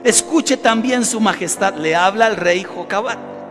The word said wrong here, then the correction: Josafat: Josafat, ya le cambié el nombre.